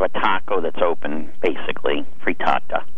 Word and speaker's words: frittata: Is it frittata? – Frittata.